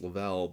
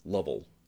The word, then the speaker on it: Lavelle: And I'm like, very few people get it right, because if you look at the way it's spelled, right, it's Lavelle, but people think it's like Lovell, yeah, you know, because it looks the way it's spelled that way.